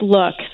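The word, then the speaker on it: Lukh: L-U-K-H. I don't know how to pronounce it or what it is. – Yeah, it's pronounced Look.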